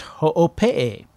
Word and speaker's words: Hoopii: Now, Ho'opee is spelled Hoopi, H-O-O-P-I-I, but it's pronounced Ho'opee.